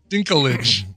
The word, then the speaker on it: Dinklage: In today's news, Peter Dinklage. I'm saying that right? – Dinklage. – Peter Dinklage.